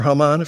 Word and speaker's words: Haman: And any decision he makes, the king's just going to say, yeah, go for it, Haman. Or Haman, if you want to pronounce it like that.